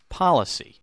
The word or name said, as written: policy